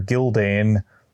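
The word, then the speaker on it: Gyldayn: namely that it's narrated from the perspective of Archmaester Gildane, yes, I pronounced that right, Archmaester Gildane of Old Town, constructing the story from a variety of unreliable primary sources.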